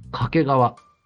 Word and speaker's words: 掛川: 2 期は掛川。作品では品川と同じアクセントでしたが、地元ではサザエさんのんが、うんうん。つまり掛川と同じ発音です。